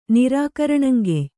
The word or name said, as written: ನಿರಾಕರಣಂಗೆಯ್